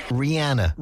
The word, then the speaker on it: Rihanna: Rihanna. – Like rhymes with Sienna. Rihanna.